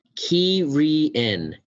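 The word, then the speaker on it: Cairhien: Oh, here it is. Pronounced Ki-ri-en. – Wow, we were way off.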